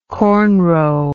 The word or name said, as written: cornrow